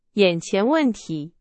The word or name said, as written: 眼前